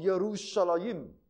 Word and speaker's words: Yerushalayim: It's either spelled as Y-O-D or J-O-D. It's Yod. Okay, in Hebrew, they have no J. So, the Y sometimes is pronounced as J in English. Like in Hebrew, is not pronounced as Jerusalem. It's called Yerushalayim. It's Y.